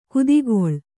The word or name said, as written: ಕುದಿಗೊಳ್ಳು